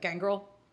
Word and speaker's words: Gangrel: Is it pronounced Gangrel? – Gangrel, probably. Gangrel? Gangrel, yeah. – Gangrel. Cool, I like that. – At least the part of Scotland I'm from, that's probably how it would be said.